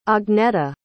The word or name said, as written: agneta